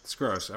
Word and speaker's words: Skroce: And I was, and for like 5 minutes, I was like, I think I'm going to go with Skroce now. And then my wife reminded me that I'd be a big idiot among my friends if, like, suddenly I had this new pronunciation, in middle age. So let's go with Skross.